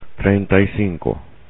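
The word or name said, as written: treinta y cinco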